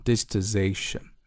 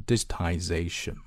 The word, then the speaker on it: digitization: As the digitization of economies races ahead，随着经济的数字化步伐加快 digitization、啊、注意这个词英音跟美音发的会不一样啊，美音的话会读成 digitization，然后英音会读成 digitization、啊、表示数字化，就是从那个 digital 那个词过来的、啊、然后呢后面这个动词用的是 races， race here means move very quickly，就是非常快速的发展。